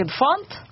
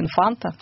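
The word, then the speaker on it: Infante: Но потом в группу пришел басист Фрэнк Инфант, или Инфанта, как правильно читается его фамилия.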